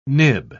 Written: nib

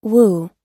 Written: wu

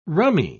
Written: rummy

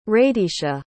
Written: raydeisha

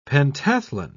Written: pentathlon

